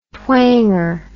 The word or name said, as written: twanger